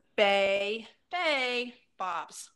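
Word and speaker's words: Bey Bae Bops: Yeah, the name of that playlist is Bay Bay Bops, but it's spelled like B-E-Y and then B-A-E and of course Bops. – Nice. – Bay Bay Bops.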